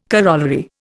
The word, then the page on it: corollary: Indian